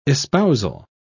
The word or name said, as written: espousal